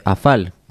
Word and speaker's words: afal: en el idioma galés se dice afal, que la F se pronuncia como una V.